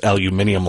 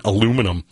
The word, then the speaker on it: aluminium: And yes, I pronounce aluminium like I'm British, because aluminium just sounds more fun than aluminum.